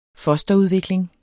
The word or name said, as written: fosterudvikling